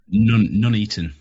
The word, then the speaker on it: Nuneaton: Everyone needs to try to go to the demo in Newington. Is that how you say it? Newington? I saw it up on the... – Nuneaton. – Nuneaton. There you go.